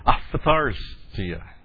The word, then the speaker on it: Aphtharsia: Because non-Greek speakers almost couldn't pronounce these things because you had a PH and then a TH in there. So it's A-P-H-T-H-A-R-S-I-A. Aphthartia. I mean, it's a really toughie.